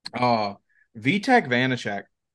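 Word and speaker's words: Vítek Vaněček: But honestly, Vitek Vanacek, if I'm saying it wrong, pardon me. – No, that was right. You were correct.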